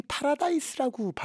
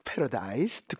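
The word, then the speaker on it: Paradise: 분명히 파라다이스라고 발음할 것 같지는 않아서 패러다이스 듣고 왔습니다.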